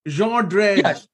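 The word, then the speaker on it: Jean Drèze: Jean Drez, obviously.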